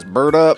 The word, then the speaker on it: Berta: That is the pronunciation. With a T, not a D, not like Berta. Like Jarvis says, Berta.